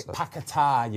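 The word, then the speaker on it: Paquetá: Paqueta, you pronounce that, by the way.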